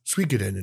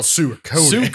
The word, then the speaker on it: Suikoden: I it's Suikoden in Japan. But us Americans, we call it Suikoden.